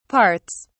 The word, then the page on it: parts: British English